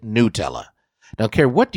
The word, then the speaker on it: Nutella: Another revealed, you know, that it's pronounced Nutella, like N-E-W, Nutella.